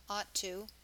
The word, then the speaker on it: ought: Notice that the GH is silent, ought to.